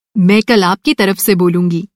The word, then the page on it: तरफ़: طرف